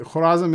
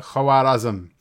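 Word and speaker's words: Khwarazm: Uh, by the way, Khurazm is the way that the locals pronounce the, the name. Uh, it's written with a waw and an alif. So in Arabic, usually they'll say Khawara, Khawarazm or Khawarizm.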